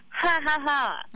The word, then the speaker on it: 555: number 5 in Thai is pronounced as ha ha ha, mm. which is mm. – mean like similar to when people laugh.